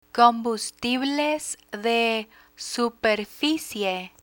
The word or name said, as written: combustibles de superficie